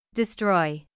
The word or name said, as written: destroy